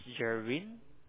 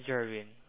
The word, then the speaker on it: Jerwin: Yeah, my name is Jerwin, and my last name is G for Golf. Yeah, Jerwin.